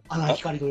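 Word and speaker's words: 阿南光: 四国か、えー、徳島かこれなんて読むのアナン光って読むのアナン光と読みます。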